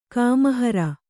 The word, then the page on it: ಕಾಮಹರ: Kannada